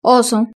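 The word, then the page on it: oso: Spanish (Spain)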